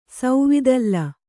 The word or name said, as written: ಸೌವಿದಲ್ಲ